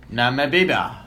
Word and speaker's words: Namibia: Regina George's doppelganger is a pageant queen in Namibia. Am I pronouncing that correctly? – Namibia.